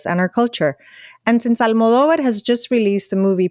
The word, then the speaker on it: Almodóvar: Almodóvar. – Almodóvar.